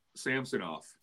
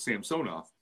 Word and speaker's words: Samsonov: And as I've found out that for a lot of the Russian names, you're supposed to put the accent on the middle syllable. That's how they say it. Okay. But when they come over here, it's just, you know, like the great Sergei Samsonov, or Samsonov, as Chuck would call him.